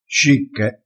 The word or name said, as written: scicche